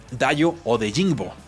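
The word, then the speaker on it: Dayo Odeyingbo: Dayo de Ningo de eh, Vanderbilt, no, creo que no lo pronuncié bien, Dayo o de Jingbo, mejor dicho,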